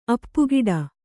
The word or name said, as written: ಅಪ್ಪುಗಿಡ